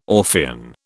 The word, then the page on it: Orphean: British English